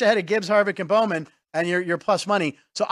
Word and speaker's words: Alex Bowman: The one we pointed out yesterday, you got Ty Gibbs, Ken Harvick, Joey Logano, Alex Bowman, not sure how you pronounce it. – Bowman. – There you go.